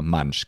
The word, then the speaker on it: Mansch: Und dann gibt es auch solche Begrifflichkeiten zum Beispiel, also Mansch oder, ja, Mansch, genau so wird es ausgesprochen.